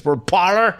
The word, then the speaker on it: Parler: And I think it's called, according to the internet, it's pronounced Parler. Somebody who did French in school, I always want to call it Parler. But since it's American, it's Parler.